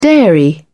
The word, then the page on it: dairy: British English